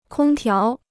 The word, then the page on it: 空调: kōng tiáo